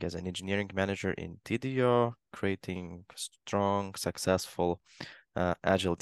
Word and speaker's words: Tidio: And uh, is from the company called Tidio, if I pronounce it correctly. Correct. Yes. yes.